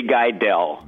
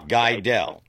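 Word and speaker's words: Gidell: Eddie Guyvel? – Eddie Guidel. – Guidel. Okay. – G with a G. G-I-D-E-L-L. That's how you pronounce it.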